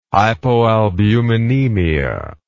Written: hypoalbuminemia

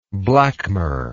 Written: blackmur

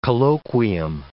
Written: colloquium

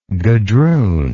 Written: gadroonage

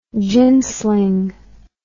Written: gin sling

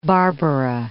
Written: Santa Barbara

